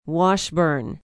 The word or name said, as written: washburn